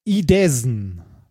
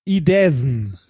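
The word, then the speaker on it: Idåsen: Er heißt Idesen. – Idesen? – Idesen. Also ein A mit so einem, also so ein Angström-A. – Das spricht man, glaube ich, O aus.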